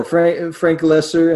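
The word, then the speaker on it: Frank Loesser: This was an older song by Frank Lesser. Is that how you pronounce his name? – Yeah, Frank Lesser, and he was a Broadway songwriter who had a stint in writing some country and western songs.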